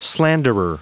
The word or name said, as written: slanderer